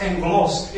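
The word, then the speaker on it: angelos (ἄγγελος): In the Greek, the word angels is pronounced angelos.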